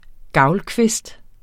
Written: gavlkvist

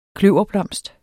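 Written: kløverblomst

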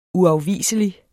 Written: uafviselig

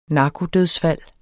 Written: narkodødsfald

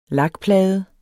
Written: lakplade

